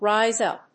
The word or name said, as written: rise up